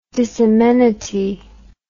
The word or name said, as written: disamenity